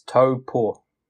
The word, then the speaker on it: Taupo: But it's same with Taupo. I think it's just become the norm for non-New Zealanders to pronounce it incorrectly. – Yeah. But, even us down south, we'll say Taupo. But it's really? – It's Taupo.